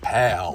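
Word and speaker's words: Pál: And I also want to point out that Paul is spelled P-A, accent over the A-L-L. – Wow. – So it's like pal.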